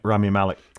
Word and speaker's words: Rami Malek: Rami Malek.